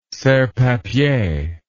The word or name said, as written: serrepapier